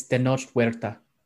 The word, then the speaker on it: Tenoch Huerta: far as far as how to pronounce it, it's Tenoch Huerta.